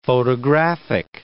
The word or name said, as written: photographic